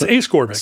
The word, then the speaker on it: ascorbic: It's the opposite of scorbic things. – It's ascorbic. – So maybe ascorbic is right. Yeah. – Ascorbic. – Here's a dictionary that has it as ascorbic.